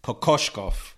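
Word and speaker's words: Kokoškov: I'm going to do my best. Kokoshkov, Igor Kokoshkov. And yes.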